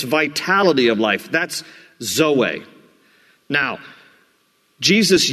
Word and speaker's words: zoe: But there's another Greek word for life, and that is zoe, Z-O-E. Usually, for a girl's name, it's pronounced Zoe. But in the Greek, the accent is on the last part, zoe, and it means life, and it means a fulfilled life.